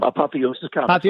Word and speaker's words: Apotheos: And you've got an event planned for here locally at Apotheos Comics. I didn't say that right. How am I saying? – Apotheos Comics. – Apotheos, uh-huh. – Yes.